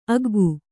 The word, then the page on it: ಆಗ್ಗೆ: āgge